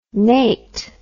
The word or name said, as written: Nate